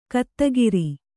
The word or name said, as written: ಗೊತ್ತುಗುರಿ